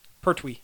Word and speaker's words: Pertwee: John Petrie. – Pertwee. – Pertwee.